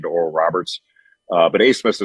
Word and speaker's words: Abmas: Um, you remember Oral Roberts beat Ohio State a couple years ago, and they still have the same guard, Max Abmas, spelled A B M A S, and it's pronounced Abmas.